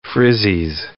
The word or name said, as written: frizzies